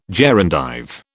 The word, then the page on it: gerundive: British English